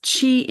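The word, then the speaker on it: qi: When they're spinning properly and flowing, each chakra allows chi energy, and that's spelled Q-I, to flow through the body.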